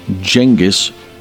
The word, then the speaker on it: Genghis: Turns out it's Genghis with a G, I know.